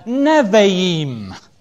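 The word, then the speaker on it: Nevi'im (נביאים): And it's spelt not as it's pronounced. It's spelt N-A-B for beta, I-I-M. And in Hebrew, a B or a Beth is pronounced as a V. So we get the word there, Nevi'im.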